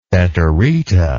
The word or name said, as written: feterita